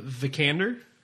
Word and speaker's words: Vikander: And then Alicia Vikander.